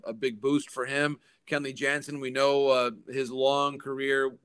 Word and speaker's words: Jansen: And that at that point, he was still preferring the Dutch pronunciation of his last name, which was, of course, Kenley Jansen. So you had Kenley Jansen, the catcher, now Kenley Jansen, the closer.